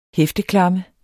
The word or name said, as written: hæfteklamme